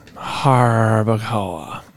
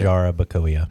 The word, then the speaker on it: Jarabacoa: Well, Harbacoa. – Okay. Jarabacoa. – Jarabacoa.